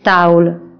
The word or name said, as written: ميز